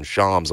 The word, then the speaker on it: Shams: But Klay, at this point, I'm sure isn't interested in going much lower and is willing to, it sounds like, from Shams, whatever y'all are calling him in terms of pronunciation today, we call him Shams on this show.